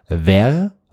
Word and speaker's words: vair: Ver, V-E-R-R-E, e, bedeutet Glas. Und das Spannende, es gibt ein zweites französisches Wort. Das spricht man eigentlich genauso aus, auch ver, und das schreibt man aber V-A-I-R, und das bedeutet Eichhörnchenfell. Ach Gott.